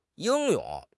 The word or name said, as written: 英勇